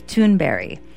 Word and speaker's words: Thunberg: She said the correct pronunciation is Thunberry.